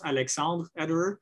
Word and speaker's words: Alexandre Ederer: So Alexander, but the R comes before the E. So pronounce Alexandre Ederer.